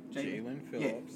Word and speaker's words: Jalen Phillips: Jalen Phillips.